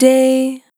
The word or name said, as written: Short Day